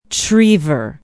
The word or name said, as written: trevor